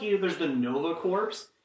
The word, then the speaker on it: Corps: The P is silent. – Corps. – The P and the S are silent.